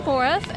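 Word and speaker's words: Boruff: My name is Ashley Boreff, and that's A-S-H-L-E-Y. Last name, B as in boy, O-R-U, F as in Frank, F as in Frank.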